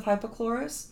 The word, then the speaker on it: hypochlorous: It's spelled H-Y-P-O-C-H-L-O-R-O-U-S, hypochlorous.